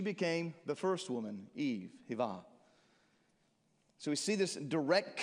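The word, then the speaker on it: Hava (חוה): In Hebrew, Eve is pronounced Hava.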